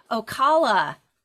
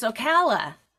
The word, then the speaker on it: Ocala: I've been saying Ocala. It's Ocala. I mispronounced it.